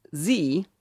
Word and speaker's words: Xee: Well, I settled on a little application called Xee. It's spelled X-E-E, and the link is really long, but it'll be in the show notes.